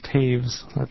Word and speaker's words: Taves: Pronounced Taves. – Taves. – Doesn't look anything like it's spelled. – Thank you very much. And welcome. – Thank you. Madam Chair, members of the committee, James Taves, the acting assistant director for the cluster on seniors and people with disabilities.